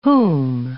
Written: whom

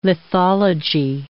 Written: lithology

